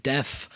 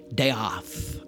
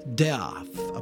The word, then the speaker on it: Deoth: This movie stars John Hurt as Giles Deoth, which can, of course, be read and is in the film as Giles Death. Right, but it is pronounced Deoth, a play on the title, and of course, its inspiration, Death in Venice.